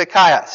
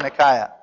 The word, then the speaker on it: Achaia: So it's a diphthong there, alpha iota, which is an I, and then there's a little subscript to iota, which would be an a. So as far as I'm concerned, it's Achaia. So from now on,